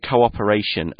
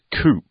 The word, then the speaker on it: cooperation: Now, be careful with this word cooperation and don't pronounce it coop, okay? It's COOP at the start, but it's two syllables, two sounds, so cooperation, not cooperation.